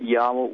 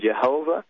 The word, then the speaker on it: Yahweh: And they said to me that Yahweh was indeed a more accurate pronunciation than Jehovah.